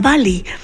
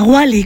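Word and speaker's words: Wally: Así concluye nuestro recorrido por la Bali, o la Wali, como prefieran ustedes pronunciarlo,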